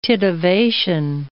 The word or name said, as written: titivation